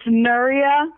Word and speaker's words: Nuria: But it's not, it's Nuria. There's no like ia.